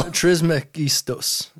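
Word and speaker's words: Trismegistus: It's a trismetikmos, by the way. You definitely didn't pronounce that correctly. – Oh, no, I put the T before the G. You're right. – Yeah, that was not real. – Trismegistos. There we go.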